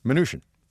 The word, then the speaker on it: Mnuchin: How do you say it? – Mnuchin. – Mnuchin, like Mnuchin. Minutia.